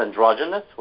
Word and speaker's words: androgynous: The word is androgynous with a G? – In English, it is. – Oh, I thought it was G. – Soft G.